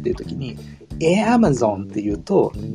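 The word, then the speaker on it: Amazon: って書いてあるじゃんって思っちゃうんですよ確かにでもですねとにかくあのアマゾンについては特に私の知る限り少なくとも西海岸のアメリカ人はあじゃなくて、えに近い発音から始まるんですよね。エアマゾンって言うと、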